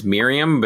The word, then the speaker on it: Mirjam: His mom name, I mean, it's Miriam, but it's spelled Mirjam.